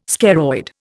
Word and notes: scaroid: Indian

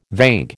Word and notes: vague: American English

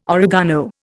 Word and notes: oregano: Indian

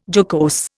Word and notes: jocose: Indian

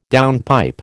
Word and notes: downpipe: American English

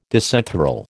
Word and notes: disenthral: American English

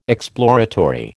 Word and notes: exploratory: American English

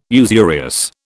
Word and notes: usurious: British English

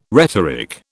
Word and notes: rhetoric: British English

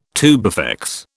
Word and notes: tubifex: British English

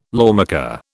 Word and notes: lawmaker: British English

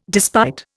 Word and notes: despite: Indian

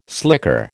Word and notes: slicker: American English

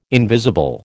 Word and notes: invisible: American English

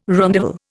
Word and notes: rondavel: Indian

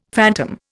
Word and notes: phantom: Indian

